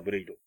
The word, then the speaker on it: 剣: 仮面ライダーブレイド。 – えー、はい剣、漢字の剣、剣って書いて、ブレイドって読ませるみたいな